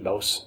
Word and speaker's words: Bose: B-O-S-E, Bose, I pronounce it Bose,